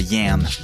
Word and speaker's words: Yen: Le morceau s'appelle Yen. Ça se prononce comme ça, Yen.